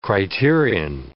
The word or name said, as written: criterion